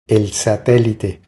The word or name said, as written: El satelite